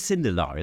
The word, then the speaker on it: Sindelar: It is Sindelar, is it? – That's it.